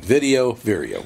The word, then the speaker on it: Vireo: Everybody down in Bloomington, just, how do you say the word again? It's Vireo? – Vireo Health. – It is Vireo. Yeah, I wanted to make sure. V-I-R-I-O, right? – V-I-R-E-O. – V-I-R-E-O.